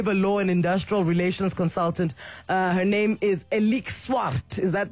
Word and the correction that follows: Alex Swart: Am I saying that correctly? – Yes, good morning. Yes, my name is Alex Swart. – Alex Swart.